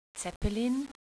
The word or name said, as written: Zeppelin